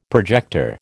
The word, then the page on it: projector: American English